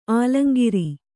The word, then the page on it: ಆಲಂಗಿರಿ: ālaŋgiri